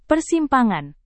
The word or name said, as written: persimpangan